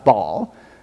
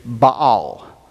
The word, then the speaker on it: Baal: Now, in the Old Testament, when you see the name of the Canaanite god Baal, you are technically supposed to pronounce both A's. So the correct pronunciation is Baal. But I just say Baal.